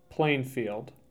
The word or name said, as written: Plainfield